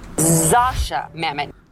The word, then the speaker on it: Zosia Mamet: So the correct way to pronounce Zosia Mamet is... – Zosia Mamet.